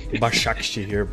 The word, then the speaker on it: Başakşehir: So I think that could be the way the group finishes up, and it could be a battle for third between Başakşehir and Wolfsburger. So apologies if I've murdered the pronunciation of that there. – That's fine. Başakşehir, but you...